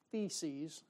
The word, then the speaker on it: theses: And I like what Martin Luther said back in the 1500s when he nailed those 95 theses, I said it right this time, to the church door.